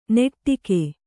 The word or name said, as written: ನೆಟ್ಟಿಕೆ